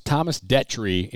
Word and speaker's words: Thomas Detry: my name's Thomas Detry, and here are five things about me." But everybody's calling him Detry. – Detry, yeah, the long E.